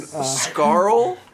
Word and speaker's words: Scurll: This it's pronounced Pico and Scarl.